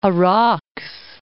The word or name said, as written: Araks